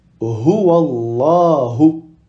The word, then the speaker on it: Huwallahu: Donc par exemple, on va dire Huwallahu. Huwallahu. Ici, on dit la.